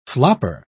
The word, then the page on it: flopper: British English